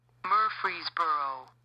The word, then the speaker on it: Murfreesboro: The pronunciation we were looking for was Murfreesboro.